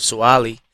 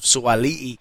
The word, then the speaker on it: Suaalii: And I just want to say, yeah, I've been calling him Joseph Suaalii. And pronunciation is huge for me. And you said I learned it was actually Joseph Suaalii. So I apologise for that as well.